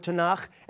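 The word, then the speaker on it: Tanakh (תנ״ך): And then we have the K sound or the H sound at the end of the word Tanakh.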